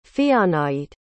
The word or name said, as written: fianait